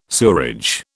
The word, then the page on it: sewerage: British English